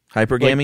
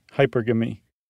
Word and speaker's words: hypergamy: Hypergamy? Like monogamy. Hypergamy.